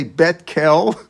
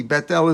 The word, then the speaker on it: Beit El: And he had this shiloh that he asked then Rav Tzvi Yehuda Cook, and do they say Beit Kel or do you say Beit El? Right? How do you say it? Is it God's name? And the answer is it's the name of the city.